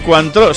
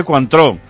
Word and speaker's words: Cointreau: Y me apunta Jesús Blanco, y Cointreau, sí señor, a mi familia de Inglaterra le llevé yo una botella de Cointreau, además pronunciado así, Cointreau, o Cointreau, ¿no? Vas a decir Cointreau.